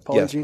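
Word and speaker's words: Polygiene: And I want to bring this up, and it's, I know the polygene, right? Is that how you say it? – Polygene? Yes, polygene.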